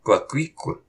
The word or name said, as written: Qaqiq